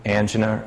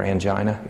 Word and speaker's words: angina: It really is mostly used for relief of chest discomfort, angina or angina, either pronunciation is correct.